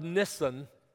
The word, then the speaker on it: Nisan: Notice in chapter 2 of the book of Nehemiah, in the month of Nisan, and by the way, that's the way that's pronounced according to Google.